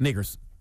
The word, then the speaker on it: Nakers: But if you say it fast, Nakers, kind of. – Nakers. – All right? Kind of. No. All right? Nakers rhymes with Lakers, Takers, Bakers, Quakers, Fakers, Shakers, Makers.